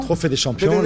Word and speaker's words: Trophée des Champions: Trophée des Champions.